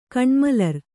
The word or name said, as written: ಕಣ್ಮಲರ್